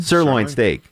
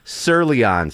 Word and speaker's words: sirloin: Sirloin steak. – Yeah. – She pronounced it Sirleon steak.